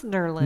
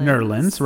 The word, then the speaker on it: New Orleans: She's from New Orleans. – I believe it's pronounced Nerlens. – Nerlens, right.